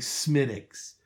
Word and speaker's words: Smithwick's: But you pronounce it like it's like Chronicles of Riddick. You know, like that movie. – I don't. – It's like Riddick, but it's Smithwick. – Okay. Yeah. I mean, that's it's like D's. – Yeah. – Like, or maybe like a very soft T, like Smithwick's. But it's, I know, I was like, I'll have a Smithwick's, and the bartender's eyes just roll back.